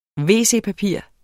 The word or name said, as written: wc-papir